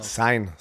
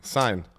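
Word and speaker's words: Cine: Äh, spricht man den Sein oder keiner? – Sein, Sein. – Also Sign, so habe ich, mit, so hab ich das gehört im Fernsehen bei denen. Sein.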